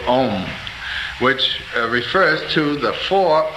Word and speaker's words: Om: The A, well, when one pronounces this syllable, it starts in the back of the mouth, ah, oo, mm, om.